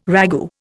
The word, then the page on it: ragout: Indian